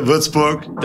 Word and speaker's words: Würzburg: Würzburg.